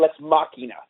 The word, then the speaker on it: Lex Machina: We call it Lex Machina. So I think like the Greek or Latin.